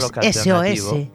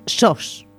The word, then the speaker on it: SOS: Sí, así que no es SOS. – Es cierto. – Es SOS, de solos. SOS, con acento en la O.